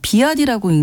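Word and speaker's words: BYD: 알파벳으로 BYD라고서 비아디라고 읽는 중국의 전기차인데요.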